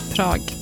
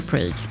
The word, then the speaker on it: Prag: Tydligen så uttalas Prag likadant på engelska som på svenska, typ. Svårt. Prag. Man vill ju säga Prague.